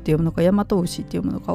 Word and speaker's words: 大和牛: この辺ね読み方ややこしいんですが、えー、大和牛と読みますということですね。